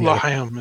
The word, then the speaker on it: L'chaim: L'chaim. Again, I wonder if I actually pronounced that correctly. You know what? – Doesn't L'chaim is good. – That's close enough. That was pretty good.